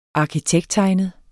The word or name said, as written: arkitekttegnet